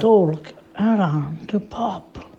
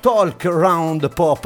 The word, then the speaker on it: Talk Round Pop: Allora, Daniela ci ha tenuto a precisare la scorsa settimana che la pronuncia corretta del suo programma è Talk Round Pop, no Talk Round Together, oh, Talk Round Pop, ma come parla?